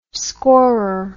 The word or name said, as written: scorer